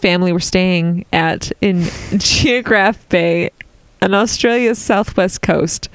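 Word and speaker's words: Geographe: Geograph Bay. Because it's spelled with an E at the end, not in a Y. So I didn't know how to say it.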